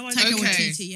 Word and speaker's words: Taika Waititi: Guys, Thrapples, Rita, Taika Waititi. Did I pronounce his name right? – Taika Waititi, yeah.